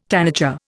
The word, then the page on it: tanager: Indian